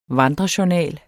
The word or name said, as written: vandrejournal